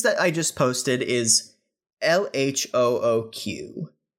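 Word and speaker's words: L.H.O.O.Q.: So LHOOQ is pronounced in the French way LHOOQ which translates to El Asho Oku,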